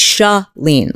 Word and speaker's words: Shaleen: It's Shaleen, like shu S H, sha, sha, like shut the door. It's not Shay.